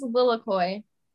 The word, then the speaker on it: Lilikoi: My name's Lilikoi.